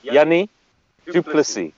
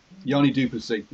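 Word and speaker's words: Jannie du Plessis: Bismarck and Johnny DePlease in that front row. – Say again? Sorry, Bismarck and Johnny DePlease. Anyway. – Yanni Duplessis. – Yanni Duplessis.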